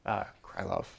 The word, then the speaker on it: Krylov: We'll go over one where you have, say, Kirilov subspaces, Krylov, I always mispronounce things,